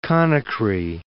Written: Conakry

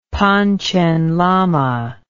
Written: Panchen Lama

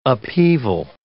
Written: upheaval

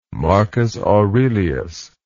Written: marcus aurelius

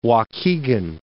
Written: Waukegan

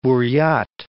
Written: Buryat Republic

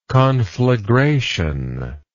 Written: conflagrative

